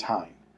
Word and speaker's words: Haim: I call them Haim. Some people call them Haim. Some people call them Haim. The way that I was told to pronounce it was Haim.